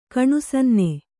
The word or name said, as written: ಕಣ್ಸನ್ನೆ